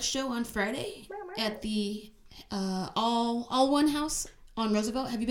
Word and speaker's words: Alwun: It's spelled weird. It's spelled like ALWUN, so All One, like, but we're all one community.